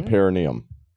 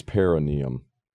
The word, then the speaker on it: perineum: Like around the hole and down the perineum. – Oh. Yeah, you gotta go in there. You gotta. – I thought it's pronounced out. – Perineum. – It's perineum.